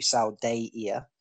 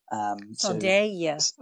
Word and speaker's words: Saldaea: I'm looking at the glossary now because for the Saldaea, which is how it's put here in the glossary, Saldaea. – Saldaea, okay. – Mm-hmm. Yeah. So S-H-A-L, and then emphasis on day. D-A-Y, and then E-E-A-H, or H, however you want to pronounce that. – Saldaea.